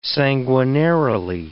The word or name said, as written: sanguinarily